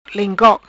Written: Lengkok

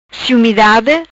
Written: Ciumidade

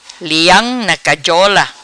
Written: Leam Na Cajola

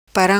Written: Param

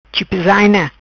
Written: Chipizaina

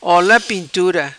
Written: ola pintura

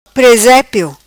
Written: Presepio

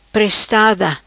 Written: Prestada

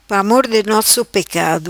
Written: Pramor di nosso pecado

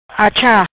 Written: Achar

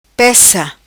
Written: Pessa

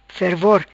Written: fervor

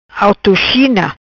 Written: Autochina

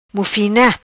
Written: Mufina

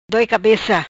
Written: Doi Cabeca

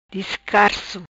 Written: Discarso

